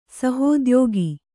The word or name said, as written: ಸಹೋದ್ಯೋಗಿ